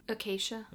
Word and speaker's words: Acacia: but how would you pronounce that? – Acacia. – There you go.